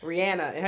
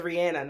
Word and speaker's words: Rihanna: That ain't Rihanna. Her name is Rihanna, not Rihanna.